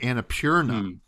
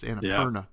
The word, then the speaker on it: Annapurna: You know, I've always pronounced it differently because I see P-U-R and I think like pure. So I always think Annapurna. That's Annapurna.